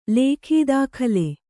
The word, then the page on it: ಲೇಖೀದಾಖಲೆ: Kannada